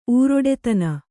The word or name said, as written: ಉರುಟುತನ